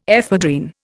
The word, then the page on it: ephedrine: Indian